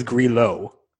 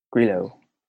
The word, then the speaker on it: Grillo: His PGA Tour profile says Grillo with an emphasis on the low. So he must have an accent on the O. But I always thought it was yo as opposed to low. – Grillo? – Yeah. – Grillo. – All right, well, we were both wrong.